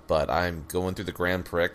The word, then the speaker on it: Grand Prix: I like the Grand Prix. – That's how I normally would have said it, like, younger, too, because I keep forgetting that it's Grand Prix. The X is silent.